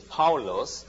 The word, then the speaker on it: Paulos: Sometimes you can call the English word is Paul, but his pronunciation is Paulos, means small or little.